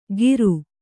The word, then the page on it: ಗೀರು: Kannada